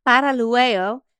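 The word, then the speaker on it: Paralluelo: You say her name better than I do. Paralueo, is that right? – Paralueo.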